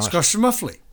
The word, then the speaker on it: Schauffele: At some point, you're going to have to give the guy a little bit of respect, and actually pronounce his name right. – Schauffele. – Yeah, there you go.